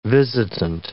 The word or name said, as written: visitant